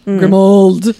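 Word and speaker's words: Grimmauld: Like mold. Grimald.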